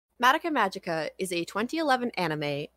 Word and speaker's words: Madoka: and that's how they say her name the entire time, Madoka. So I guess we were wrong, even though it feels like an anglicization.